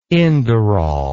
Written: inderal

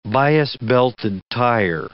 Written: bias-belted tire

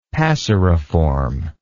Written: passeriform

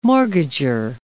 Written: mortgager

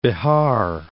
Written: Bihar

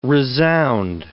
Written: resound

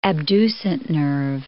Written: abducent nerve

